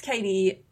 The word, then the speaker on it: Cady: Max Cady, and I should preface, Cady is spelled C-A-D-Y, like Cady Heron in Mean Girls.